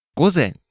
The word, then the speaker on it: 午前: 午前午前 AM.